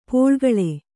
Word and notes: ಪೋಳ್ಗಳೆ: pōḷgaḷe

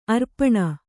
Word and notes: ಆರೋಪಣ: ārōpaṇa

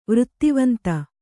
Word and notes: ವೃತ್ತಿವಂತ: vřttivanta

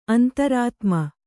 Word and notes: ಅಂತರಾತ್ಮ: antarātma

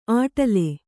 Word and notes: ಆಟಲೆ: āṭale

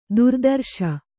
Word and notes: ಥರಥರಿಸು: Kannada

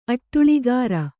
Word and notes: ಅಟ್ಟುಳಿಗಾರ: kannada

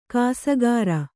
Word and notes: ಕಾಸಗಾರ: kāsagāra

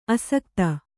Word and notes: ಅಸಕ್ತ: Kannada

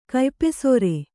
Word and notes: ಕಯ್ಪೆಸರ: kaypesara

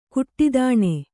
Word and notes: ಘಟ್ಟಿತನ: Kannada

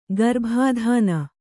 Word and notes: ಗರ್ಭಾಧಾನ: garbhādhāna

